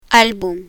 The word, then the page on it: album: spanish